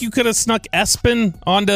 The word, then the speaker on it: ESPN: Now, the SIDs of the team, you know, whoever, they'll say, no, it's pronounced Espen, but it's spelled ESPN and then last name Lamprun.